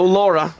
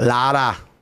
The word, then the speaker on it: Lara: No Laura. Lara with just an A. – Yeah. – Dominican name. – Yeah, it's actually Lara, but I won't make you roll your R. Oh, Lara.